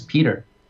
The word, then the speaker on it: Pieter: But what's worse is that my middle name is Peter, but it's spelled Piter. P-I-E-T-E-R. It's the Dutch way.